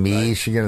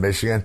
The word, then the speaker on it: Michigan: And I had this feature called Michigan Mondays, you know, using the Bob Ufer pronunciation, Michigan instead of Michigan.